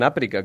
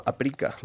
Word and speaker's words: Aprica: Y estaba, pues, en la meta, en Aprica, que me di cuenta que allí no lo pronuncian Aprica, que es lo típico que se pronuncia aquí, sino Aprica, es decir, es llana.